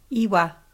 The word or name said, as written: iwa